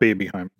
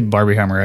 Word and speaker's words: Barbenheimer: No, you got Barbenheimer. – It. – Okay. Not Babyheimer. Barbenheimer. It's just one too many hits with a snake. – I keep going back. And when you say Barbenheimer, I go back to think of the thing, the claw thing from Toy Story.